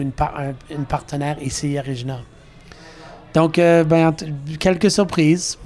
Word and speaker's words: Regina: Regina, en anglais, ça prononce Regina.